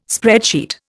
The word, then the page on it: spreadsheet: Indian